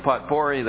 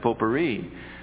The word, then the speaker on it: potpourri: And Harold came to me after the service and said to me, Pastor, he said, the word you used this morning, potpourri, the correct pronunciation of that is potpourri.